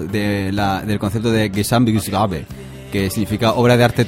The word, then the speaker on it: Gesamtkunstwerk: Era Gesamtkunstwerk.